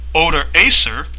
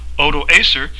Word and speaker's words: Odoacer: was forced by Odoacer, and that's spelled O-D-O-A-C-R, Odoacer, when Odoacer the Heruli forced Augustulus to quit his imperial dignity.